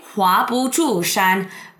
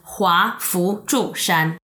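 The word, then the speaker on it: Hua Fu Zhu Shan (華不注山): This is very, very interesting because when you read the written words, it's actually Hua Bu Zhu Shan, but locally, it is actually pronounced Hua Fu Zhu Shan.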